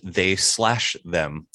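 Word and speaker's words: They/Them: I'm sorry, it is pronounced They Slash Them.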